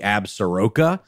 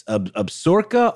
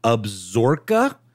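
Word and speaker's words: Absaroka: And by the way, it's confusing because it's spelled like it's got an extra syllable. It doesn't exist. Like it should be Absoroka, but it's Absorica, alternately Absorca.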